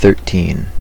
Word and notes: thirteen: American English; stressed

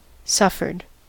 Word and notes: suffered: American English

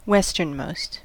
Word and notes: westernmost: American English

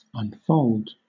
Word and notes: unfold: British English

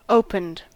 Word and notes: opened: American English

